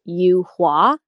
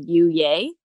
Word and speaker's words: Yuye: And so I would tell people, even though it's spelled Y-U-Y-E, that it was pronounced Yu Hua, and that it was a flower. I just thought it sounded a lot cooler than you, yay.